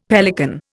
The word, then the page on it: pelican: Indian